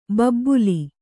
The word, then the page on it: ಬಬ್ಬುಲಿ: kannada